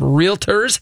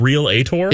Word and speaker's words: realtor: And folks, just to be clear, it's realtor. It's not realtor. So I'm just throwing that out there. – I'm pretty sure it's pronounced real ator. – It is not. – It goes from real straight to tour. – Tour. Real tour. – There is no There's no reala.